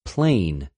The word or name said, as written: πλην